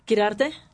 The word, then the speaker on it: Chiarty: Chiarty. – Chiarty. Oh, you truly Chiarty. – Are. You trill the R, correct? – Yes.